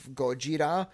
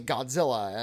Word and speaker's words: Gojira: for clarity's sake, we have to continue with the bowdlerized pronunciation of Gojira. We say Godzilla, and that's the way it was mispronounced in the 1950s, and we just kind of have to roll with that.